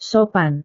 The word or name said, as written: sopan